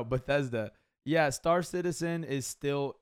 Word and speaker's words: Bethesda: I think I was saying it wrong. Bethesda.